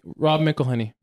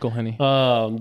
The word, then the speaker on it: Rob McElhenney: Rob McElhenney.